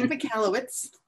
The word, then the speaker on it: Michalowicz: Mike Michalowicz.